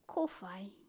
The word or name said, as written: kowhai